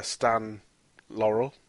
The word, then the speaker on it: Stan Laurel: And then the skinny British one is Stan Laurel. Have I said that right? – Yeah.